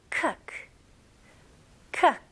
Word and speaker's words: cook: 여기서는 많은 분들이 cook이라고 발음하시죠? 아닙니다. 이거는 으 발음과 더 가깝고요. 그래서 cook, cook 좋아요. 이거는 look, book, took 다 똑같은 발음이에요.